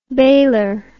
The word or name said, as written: baler